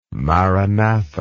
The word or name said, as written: maranatha